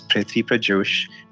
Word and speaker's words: Prithvi Prajosh: My name is Prithvi Prajosh.